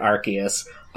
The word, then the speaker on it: Arceus: When they made the anime, they were like, we can't have Arce in a Pokemon's name, otherwise we will have to change it for Britain. So they pronounced it Arceus.